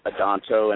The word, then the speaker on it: Adanto: My last name is spelled ADANN as in Nancy, TO, Robert Adanto.